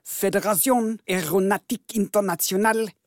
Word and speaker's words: Fédération Aéronautique Internationale: Så enligt FAI, Fédération Aéronautique Internationale.